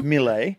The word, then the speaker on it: Mile: Well, it's spelled Mile. But it's Mille and a very good player for the Socceroos.